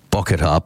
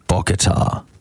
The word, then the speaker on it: Bocchetta: I'll spell it for you. It's BOCCHETTA. Bokatar. BOCCHETTA.